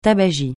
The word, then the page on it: tabagie: francais